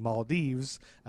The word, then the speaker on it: Maldives: And then I found 7,000 pronunciations saying Maldives.